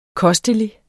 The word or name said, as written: kostelig